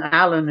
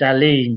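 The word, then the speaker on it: Alun: It's spelt with a U, A-L-U-N, which is the Welsh way, which most people read as Alan. In actual fact, it's Aileen, but nobody's ever called me Aileen.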